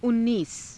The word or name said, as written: unniisa